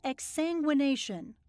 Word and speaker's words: exsanguination: It's called exsanguination.